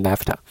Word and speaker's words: NAFTA: 标题他说：“America's deal with Mexico will make NAFTA worse。” 首先，大家可能先注意到这个 NAFTA，NAFTA 就是北美自由贸易协定。一般缩略语都不会去把它直接读出来，但这个 NAFTA 比较特殊，大家可以直接读成 NAFTA，就说的是美国和墨西哥的 deal。